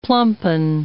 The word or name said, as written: plumpen